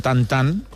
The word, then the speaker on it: Tintin: La N se nasalitza. Seria tan com Michelin, és a dir, no dirien Tintin con nosaltres.